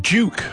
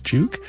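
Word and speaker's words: JVKE: We heard Duke, although it's spelled J-V-K-E. I think it's pronounced Duke.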